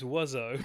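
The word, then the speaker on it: Oizo: Quentin Dupieux, who some people might know better by his other name, which is easier to pronounce, Mr. Ozo. – I think it's Wuzzo. I've certainly always known it as Wuzzo.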